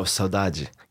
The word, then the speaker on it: saudade: saudade. – Oh, saudade. Yeah, saudade. It's such a good word. – Such a beautiful, and I guess it's really hard to really translate. – Yeah, saudade is,